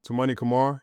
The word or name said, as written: Toumani Camara